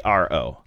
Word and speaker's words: Miro: Miro, pronounced Miro, or as we were told specifically, it's not Miro, it's Miro.